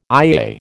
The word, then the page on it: IAA: American English